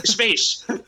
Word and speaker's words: Schweiz: Schweiz. – Ja, Gustav Fridolin hade ju lite problem med det för några år sedan, men det stämmer. Schweiz.